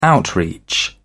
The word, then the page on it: outreach: British English